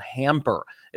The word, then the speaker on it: Hampr: It's called Hamper. It's spelled H-A-M-P-R, Hamper.